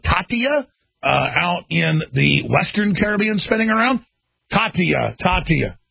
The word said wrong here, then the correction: Katia: How do you say it? Katia with a K.